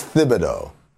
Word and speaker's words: Thibodeau: He says it's Thibodeau. That's how he pronounces his own name.